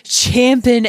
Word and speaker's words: champing: Should we just share away, Blake, as I know that you're champing at the bit. – Thank you for saying it correctly.